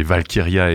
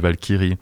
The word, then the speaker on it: Valkyria: Ils disent les Valkyria et pas les Valkyries.